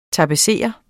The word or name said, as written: tapetserer